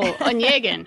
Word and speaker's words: Onegin: We have Onegin. Am I saying that right? – No, Onegin.